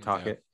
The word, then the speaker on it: Tocchet: Rick Tocchet era. Is that how you say it? Tocchet. Yeah. Mm-hmm.